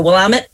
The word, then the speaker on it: Willamette: Oh, Willamette. I always say it wrong. Willamette. It either rhymes with damn it or vomit.